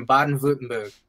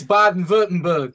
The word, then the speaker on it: Baden-Württemberg: Like so my family's mostly pretty much all the Germans are from Baden-Württemberg. And that's how you say it. It's fun to say. When I first read it, I was like, Baden-Württemberg? That sounds lame. But it's Baden-Württemberg.